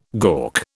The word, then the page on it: gawk: British English